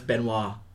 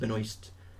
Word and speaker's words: Benoist: Aye, because unless it's a silent S, then it's Benoit. I've only heard it being pronounced Benoist.